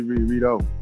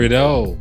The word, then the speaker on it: Redux: Roddy Ricch, Beano Redux. How do you say that? You think I said that right? – I say Redo. – Like, Redo. There you go.